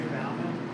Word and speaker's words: Bauman: Gary Bauman.